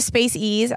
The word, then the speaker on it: Space Ease: Am I saying it right? – Mm-hmm. – And it's like Space Ease, right? – Yes, yeah. Yes. – I go to Space Ease.